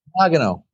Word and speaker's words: Nagano: Women's Olympic Ice Hockey Team, which won a gold medal in Nagano? Is that how you say it, Coach? – Nagano, actually. – Nagano, okay.